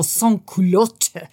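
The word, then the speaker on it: sans-culotte: how do I pronounce sans culotte in French? – But you got the pronunciation down under the wire, so we're all good. – I don't think so. He kept saying, no, sans culotte.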